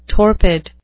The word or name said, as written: torpid